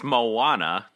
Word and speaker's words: Moana: Normally, I've just seen it M-O-N-A, but apparently they spell it all weird. – I think you're saying there's an extra A in there. – It's Moana.